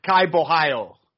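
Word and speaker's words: Borralho: Gadzi Omar Gadziev versus Kayao Bochalo. I can't remember how to say his name. Say his name. – It's Kai Bohio.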